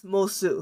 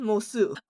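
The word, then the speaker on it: Moço: I just want you to know that it's spelled M-O-C-O, but it's pronounced Mosu.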